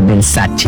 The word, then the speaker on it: Versace: Por ejemplo, Versace, cuando la marca Versace es italiana, entonces tengo que pronunciar Versace.